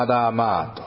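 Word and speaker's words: ア ダ マ ー (אדמה): このアダマ、土っていうのは、まあ、原文ではアダマーと、正しくは発音するんですが、赤いという言葉から来ていますね。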